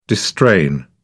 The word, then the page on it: distrain: British English